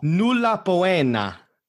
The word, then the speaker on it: nulla poena: Nulla poena.